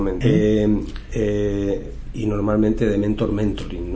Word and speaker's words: Mentoring: no sé si se pronuncia exactamente así. – Mentoring, luego. Sí, sí, sí.